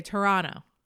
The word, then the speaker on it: Toronto: So the way that you would want to say the way you're supposed to say Toronto is not by pronouncing the second T. You're supposed to say Toronto.